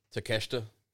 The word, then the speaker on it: Takeshita: Rampage kicked off with Jon Moxley versus uh, Takeshita, which I believe is how you pronounce his name.